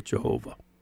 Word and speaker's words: Jehovah: But later on, Christians took those vowels in the 11th century and said, oh, this is how you pronounce it. And they would use the vowels of Adonai, but with the consonants of the divine name, and they came up with Jehovah.